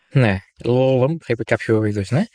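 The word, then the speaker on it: Lulham: Και κέρδισε μαζί με τον Chris Λάλαμ, θα τονίσω έτσι το όνομά του, θα το προφέρω έτσι μάλλον το όνομά του. Γράφεται Λάλχαμ, εικάζω ότι το H είναι silent, το είχαμε, δεν υπάρχει.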